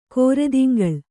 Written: ಕರ್ದಿಂಗಳ್

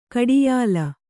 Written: ಗಾಢಾಯ್ಲ

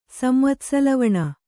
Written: ಸಂವತ್ಸಲವಣ

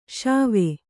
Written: ಶೇವ

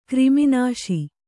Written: ಕ್ರಿಮಿನಾಶಿ

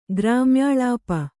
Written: ಗ್ರಾಮ್ಯಾಳಾಪ

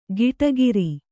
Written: ಗಿಟಗಿರಿ

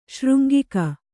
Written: ಶೃಂಗಿಕ

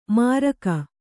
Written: ಮಾರಕ

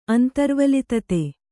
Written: ಅಂತರ್ವಲಿತತೆ

